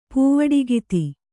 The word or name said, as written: ಪೂವಾಡಗಿತ್ತಿ